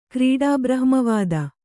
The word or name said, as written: ಕ್ರೀಡಾಬ್ರಹ್ಮವಾದ